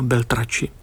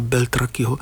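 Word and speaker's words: Beltracchi: Snad ho vyslovuji dobře, protože tam ta německá výslovnost, nikoli ta italská, Beltraci, tak tedy Wolfganga Beltrakiho, který bude vystavovat v Praze své božské příběhy.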